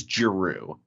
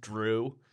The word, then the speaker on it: Jrue: So his name is Jrue. J-R-U-E. – But it's like his brothers' names are... – What's that? – It's basically pronounced Drew. – It's like D-R-E-W.